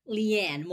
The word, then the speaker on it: Le-an: Leanne. More of a Y in between my Lee and Ann, but yeah.